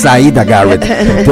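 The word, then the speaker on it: Siedah Garrett: Así es, y bueno, ya extrañaba la pronunciación perfecta que tienes, porque sí es Zaira Garrett, tienes toda la razón.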